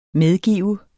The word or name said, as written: medgive